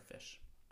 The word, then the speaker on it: Phish: It's actually, if it makes you feel better, it's spelled P-H-I-S-H for fish.